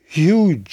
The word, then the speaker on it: Huw: C'est en 1976 que le chanteur Hugh Jones, Hugh s'écrit H-U-W, donc c'est en 1976 que ce chanteur enregistra l'album où figure cette version galloise du Déserteur.